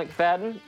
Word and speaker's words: McFadin: You can follow me on Twitter at Daniel McFadden, all one word, and my last name is spelled MCFADIN, in case you didn't read this episode description.